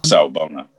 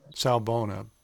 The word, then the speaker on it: Sawubona: see if I'm saying it right, Sawubona? – Sawubona. – Sawubona.